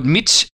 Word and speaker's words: mici: Yeah, it's called mici, which is spelled actually M-I-C-I, but pronounced mici,